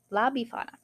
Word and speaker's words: La Befana: Hang on, I've got a pronunciation thing. Befana. Befana? La Befana.